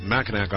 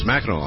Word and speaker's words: Mackinac: And one other thing that I had 10,000 corrections on, it is not Mackinac Island, even though it appears to be and should be pronounced Mackinac. It's Mackinac Island, and so thank all of you who corrected me on that.